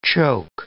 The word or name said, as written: choke